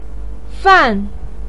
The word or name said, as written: fan4